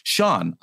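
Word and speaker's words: Siân: But the Sean is spelled S-I-A-N. So I was like, is it Sian? Sian? Sian? Sean, I'm an asshole.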